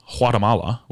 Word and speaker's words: Guatemala: My roommates, back when they were together, the good old days, they took a trip to Guatemala, which is how it's pronounced, and brought me back a hat with a chicken on it.